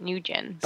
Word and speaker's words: Nguyen: I don't know how do you say it, but I had a guy in my school that was last name, but he pronounced it Nguyen.